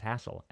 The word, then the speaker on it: Hasil: My next little blurb is going to be about Hassel Adkins, who I always thought was, his name was Hazel Adkins. And I've listened to him in the past probably for, 20 or 30 years we've been talking about him. His name is pronounced Hassel Adkins, by the way.